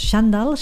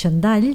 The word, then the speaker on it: xandall: Ja per començar hem d'aclarir la pronúncia, que no és xandall, sinó que és xandall, i que tots sabem que és un conjunt de jersei i pantalons llargs, amples, còmodes.